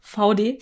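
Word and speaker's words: Vaude: Wir haben nämlich heute ein Video geschaut, wie dieses wie diese Marke ausgesprochen wird, VD. Ich bin begeistert, ich wollte es immer französisch aussprechen.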